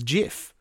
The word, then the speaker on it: GIF: But he maintained that the pronunciation was GIF. But everyone else who comes into contact with the word pronounces GIF. It with a hard G.